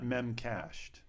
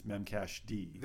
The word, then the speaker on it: memcached: It's not memcached. It's memcached.